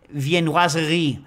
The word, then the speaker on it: viennoiserie: I also wanted to recommend sourdough panettone and viennoiserie. How do you pronounce that accurately? – Viennoiserie, you're pretty good.